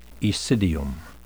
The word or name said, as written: aecidium